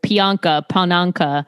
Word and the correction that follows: Priyanka: I'm so bad at names. Pianka, right? – No. Priyanka. – Priyanka.